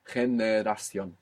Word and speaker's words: Generación: You had a little bit slipping in from English, right? One of the vowels there. But if you take it syllable by syllable, we get every vowel perfect. Generación.